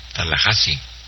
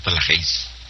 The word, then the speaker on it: Tallahassee: Se pronuncia Tallahassee, no Tallahassee.